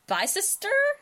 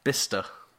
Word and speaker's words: Bicester: Bicester? That was wrong. – Bicester? That is what I've been saying for years. Okay. That is wrong. – It's wrong. – That is wrong. – How do you say it? – Bister. – Bister? That's Bister. – There's definitely looks like another syllable in there. – So B-I-C-E-S-T-E-R.